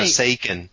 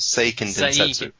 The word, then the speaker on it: Seiken Densetsu: Well, no, Seiken. It's three syllables. Seiken Densetsu.